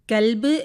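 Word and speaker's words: കൊൽബ് (قلب): കൽബ് എന്ന് പറയുമ്പോൾ നായ എന്നർത്ഥം വരുന്നു. എന്നാൽ കൊൽബ് എന്ന് വരുമ്പോൾ അതിന് ഹൃദയം എന്നാണ് അർത്ഥം വരുന്നത്.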